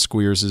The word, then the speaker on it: Squeers: Ronald Squeers, I believe that's the pronunciation. Is it right? I've seen that name before pronounced Squeers. Am I correct? – Mm-hmm.